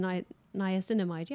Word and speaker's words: niacinamide: The Super Pure, which is niacinamide, which I can never pronounce, but it's basically just niacinamide. Yeah, I said it right.